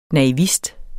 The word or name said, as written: naivist